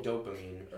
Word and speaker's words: dopamine: Propamine. – Makes your heart sweaty? – Oh, dopamine.